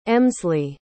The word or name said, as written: emsley